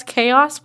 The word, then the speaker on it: chaos: But we called it, I think it was, because I didn't know how to pronounce chaos when I read it. So I thought it said Twin Chahos. But it's Twin Chaos.